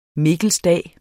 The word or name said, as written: mikkelsdag